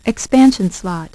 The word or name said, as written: expansion slot